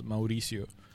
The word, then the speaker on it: Mauricio: Mauricio.